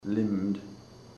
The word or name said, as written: limbed